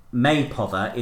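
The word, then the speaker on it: Mapother: The name Maypother is pronounced like May Bother, only with a P.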